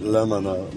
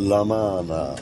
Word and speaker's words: Lamana: Met de klemtoon heeft men mij verzekerd op de tweede lettergreep. Niet Lamana, maar Lamana.